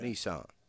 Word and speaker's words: Nissan: Are we going to not pronounce it the American way? – Nissan. – Nissan? – If you want to be so K-N-E-E-S-A-N. Yeah. Nissan.